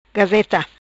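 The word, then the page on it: Gazeta: patua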